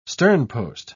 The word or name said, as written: sternpost